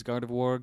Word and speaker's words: Gardevoir: Is that how you pronounce that? I thought it was Gardevoir. – I believe it is Gardevoir. Gardevoir is what I grew up saying.